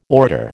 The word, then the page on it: orator: American English